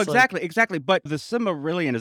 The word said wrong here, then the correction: Silmarillion: is that how you say it? Yeah, Cimmer. Silmarillion.